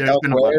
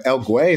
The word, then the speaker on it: Elway: It was like Elway or something like that. – What was it? uh, El Guay. Is that how you pronounce it? Oh yeah, John El Way.